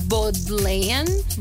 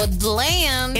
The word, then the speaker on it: Bodleian: Bodleian. – Bueno, Bodleian.